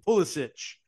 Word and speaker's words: Pulisic: Is it Pulisic? – Is that how you pronounce it? – Yeah.